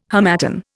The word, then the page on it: harmattan: Indian